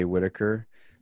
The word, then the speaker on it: Whittaker: Just follow me on Twitter and Instagram at It's Jay Whittaker.